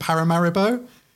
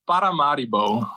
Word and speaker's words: Paramaribo: That's the English pronunciation. – Okay, what's the Surinamese? Oh God, I can't even say Surinamese. How do you pronounce it, please? So terrible. – Paramaribo. – Paramaribo. Okay, cool. – Paramaribo. It's with the rolling R and this short syllables.